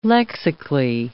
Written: lexically

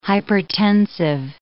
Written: hypertensive

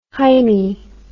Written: heimie

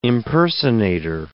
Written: impersonator

